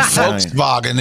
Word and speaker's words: Volkswagen: Volkswagen is the right way to say that.